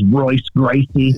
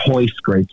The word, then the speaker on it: Royce Gracie: There's this guy, his name's Royce Gracie. – Yeah, and that's not even how you pronounce it. It's Hoist Gracie,